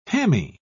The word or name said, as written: hammy